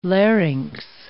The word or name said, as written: larynx